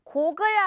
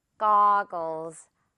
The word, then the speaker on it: goggles: 고글 아니고 goggles. 거라고 느껴질 수도 있고 약간 가에 가깝게 느껴질 수도 있어요, 그건.